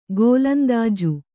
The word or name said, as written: ಗೋಲಂದಾಜು